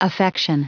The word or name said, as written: affection